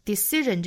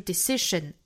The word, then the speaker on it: decision: Decision 这个单词的中间呢是清辅音词，后面的这个审变成了忍的发音，因为前面一个音素是元音，这种情况呢都是要把这个清辅音齿浊化成日，所以这个词不是 decision 而是 decision.